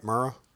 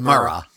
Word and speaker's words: Murrah: Murrah? – I think so. That's what I'm going with. – Alfred Murrah.